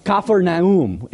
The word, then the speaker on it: Capernaum: We're at Capernaum, or Capernaum is how it would be pronounced, and it means village of comfort.